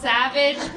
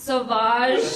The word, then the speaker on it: Sauvage: If Johnny Depp has a fragrance called Savage, pronounced Sauvage.